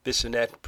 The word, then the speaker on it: Bisonette: And we're going to begin with a little visit to the Bissonnette, pronounced Bissonnet, or Bissonnet, pronounced Bissonnet. I'm not really sure what I'm supposed to do with that. It says B-I-S-O-N-E-T-T-E, and then it's pronounced Bissonnet. Go figure.